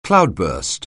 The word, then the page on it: cloudburst: anglais